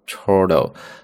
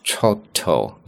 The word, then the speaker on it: chortle: chortle，注意啊，本来英式发音读作 chortle，那么美式发音 chortle。